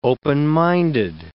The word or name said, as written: open-minded